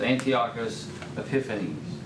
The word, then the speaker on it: Antiochus Epiphanes: Among the Seleucids, came the King Antiochus the Fourth, sometimes who referred to himself as Antiochus Epiphanes. That's the pronunciation I heard last. That's what I'm going to stick with.